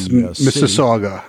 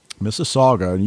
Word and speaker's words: Mississauga: Born in Ontario, I'm not even going to try to pronounce the Mississauga. – City. Mississauga, you know that from hockey probably.